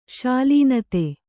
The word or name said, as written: ಶಾಲೀನತೆ